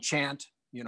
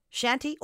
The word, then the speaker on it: shanty: And I believe most people are saying shanty, and as the CH, and I believe in the French language, quite often is soft, like sh. But it's derived from chant, you know, and the repeated work and that type of thing. So that's how I pronounce it, sea shanties. – So long story short, shanty or chanty, you're fine either way.